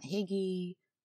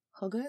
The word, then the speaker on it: hygge: That's spelled H-Y-G-G-E. I don't even know if I'm saying it right, but I know it's closer to hygge. And not Higgy, or Huggy, or Hygge. It's a Danish word.